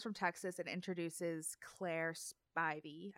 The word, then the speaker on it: Spivey: I always don't know how to say it, but they even said it on there, and it is Spivey. – It's Spivey, and I just think of it as Ivy with a sp. – I think of Spider. Spivey.